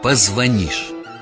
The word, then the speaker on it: позвонишь: Многие неправильно произносят слово «позвонишь». Запомните, надо говорить «позвонишь». Ты мне позвонишь, я тебе позвоню.